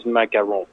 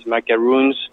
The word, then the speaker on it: macaron: The macaron, so the pronunciation is macaron. The easy pronunciation is macaroons, but macarons and macaron are two different type of cookie.